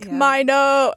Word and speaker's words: Mino: And I was like, okay, I don't know if he's saying it Mino because of just the way. The rapping style. – Uh-huh. – Or if, like, that's actually how you say his name. But then I was like, wait, no, Bobby called him on Kingdom. So I found that clip. And they were like, Mino.